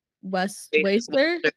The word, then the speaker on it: WSTR: We've got Sand Atlantic, Waster. – Yeah. It's WSTR.